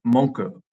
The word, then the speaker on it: Monk: The Japanese end nearly every syllable with a vowel sound, so the final word sounds more like monku than monk.